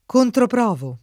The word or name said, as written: controprovare